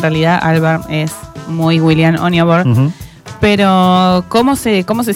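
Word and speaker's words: Onyeabor: Oniabor.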